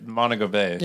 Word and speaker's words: Montego Bay: Montego Bay.